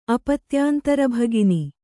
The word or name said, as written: ಅಪತ್ಯಾಂತರಭಗಿನಿ